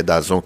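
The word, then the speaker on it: DAZN: Então, se, se alguém quiser conseguir acompanhar jogos da Copa Ouro, da Copa Africana de Nações, só tem na, naquela Dasna, tá, tá, suaco, dizem que é Dazon, que eu não consigo me acostumar com essa pronúncia, mas enfim.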